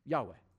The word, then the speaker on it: Yahweh: And so, our absolute best guess is that these four consonants without vowels would be pronounced Yahweh. Begin. We don't actually know.